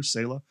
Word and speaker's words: Selah: That it's just, that's how it's pronounced in Hebrew, Selah.